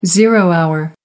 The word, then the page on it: zero hour: American English